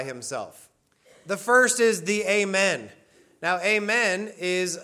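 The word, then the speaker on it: Amen (אמן): That's how you say it in Hebrew, is Amen.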